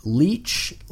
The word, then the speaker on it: Leitch: David, I don't know how to say his last name. It's Leitch. It's Leitch.